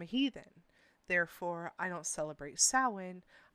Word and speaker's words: Samhain: I know that a lot of people watch Supernatural, and I remember the episode where they called it Samhain. It is not Samhain. It is a Gaelic word, and the proper way to pronounce it is Samhain.